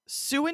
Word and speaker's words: Sault: So these words, Sault and Sioux, S-A-U-L-T and S-I-O-U-X, sound alike, but they are not related. Both do come from French, hence all of the letters we don't pronounce.